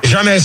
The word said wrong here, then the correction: James: C'est pas jamais, mais James.